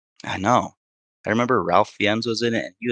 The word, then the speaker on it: Fiennes: Ralph Fiennes, yeah. I think that's who you're talking about. You said Ralph Fiennes, but I think you mean Ralph.